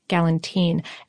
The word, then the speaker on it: galantine: There was also a specific dish I found called galantine. – Ah. – Galentine. No, it's spelled like Galentine's Day, but I think it's pronounced Galentine.